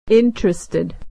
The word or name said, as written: interested